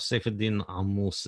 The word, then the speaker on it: Saifedean Ammous: Saif al-Din Al-Mous is how it's pronounced in Arabic, but Saif al-Din Al-Mous is good enough in English.